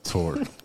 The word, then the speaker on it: TORP: TORP. – Two-year funded SAMHSA grant. – Two-year funded SAMHSA grant. But the P is silent, right? – The P is silent. – Tor. – No torps. – Just Tor.